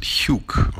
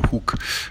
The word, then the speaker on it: Hug: Hi there, my name is Toby or Tobias, and my surname is Hug. In German we pronounce it Hug or Hug.